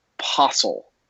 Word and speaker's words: Possel: There's a guy, a poker player, named Mike Possel, all right, and it's spelled like apostle, but without the A, all right, Mike Possel.